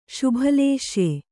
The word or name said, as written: ಶುಭಲೇಶ್ಯೆ